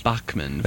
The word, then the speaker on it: Bachman: I would like to note for those wondering, Joel, that you were pronouncing it Bachman. – Mm-hmm. – And that is how the Canadians pronounce the name. Yes. Bachman.